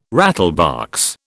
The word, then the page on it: rattlebox: British English